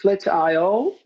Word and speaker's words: Flatio: It's Flatio, like Flatio, the AI should you know, be there, because like output, input, output, you know, one and zeros in IT, because we're purely online. But yeah, Flatio is correct.